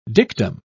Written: dictum

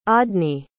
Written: audny

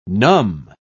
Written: numb

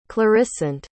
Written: clarissant

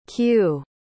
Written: kew

